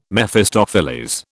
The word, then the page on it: Mephistopheles: British English